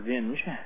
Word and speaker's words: Vinge: And there's a retired computer scientist at UCLA, his name is Werner Vinge, or Vinge, as some people pronounce it, who has actually talked exactly about this, is...